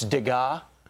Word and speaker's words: Degas: And Will, you told me I mispronounced the artist's name. – Yeah. – It's Degas.